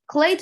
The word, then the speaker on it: Klay: His name rhymes with hay. – Well, no, you're wrong. It actually is Thompson, yeah. I'm joking. Yeah, Klay Thompson is one of the largest snubs, in my opinion, from this top 75 list.